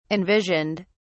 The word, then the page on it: envisioned: British English